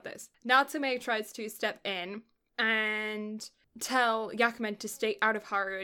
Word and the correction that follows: Yamaken: Yamaken, I've been saying this wrong the whole time,